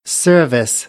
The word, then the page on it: service: American English; ɝː